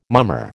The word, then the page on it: mummer: American English